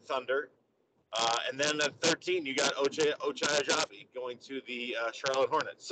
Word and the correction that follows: Agbaji: So Agbaji. You don't pronounce the G.